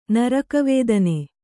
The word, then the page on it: ನರಕವೇದನೆ: Kannada